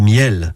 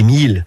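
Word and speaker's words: mielles: Ce sont les miels, qu'on prononce aussi les milles, du côté de Barneville-Cartrais.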